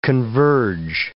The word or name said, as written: converge